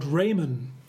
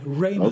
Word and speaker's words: Reamonn: Raymon. R-E-A-M-O-N-N. Raymon.